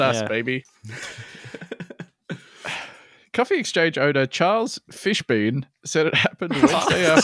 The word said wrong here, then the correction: Fishbein: It's spelled Fishbein. So it could be Fishbein. – Right, Fishbein to me.